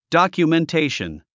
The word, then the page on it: documentation: dὰkjumentéiʃən; ドキュメンテイション